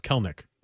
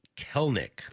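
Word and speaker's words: Kelenic: This but text, by the way, says it's two syllables, Kelnick. – Kelnick.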